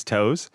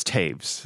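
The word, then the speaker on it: Toews: Probably my most common mispronunciation is Toes. But it's Taves. The O-E makes an A and the W makes a V.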